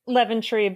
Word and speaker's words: Leventry: Did I say it right? – Leventry, but you know. – Leventry. It's the wrong accent.